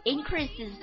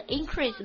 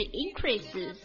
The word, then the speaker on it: increases: increases 这里的 increase 没有读成 increase 我读的是 increases 它表示名词的时候呢重音会放到前面去